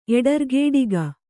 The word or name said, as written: ಎಡರ್ಗೇಡಿಗ